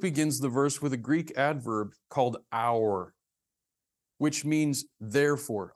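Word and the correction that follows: oun (οὖν): I'm sorry, I pronounced that wrong. I can't read my chicken scratch. It's called oun. Oun, O-U-N, which means therefore.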